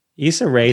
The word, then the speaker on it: Issa Rae: Issa Rae to me is like hilarious.